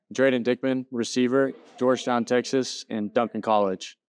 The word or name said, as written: Drayden Dickmann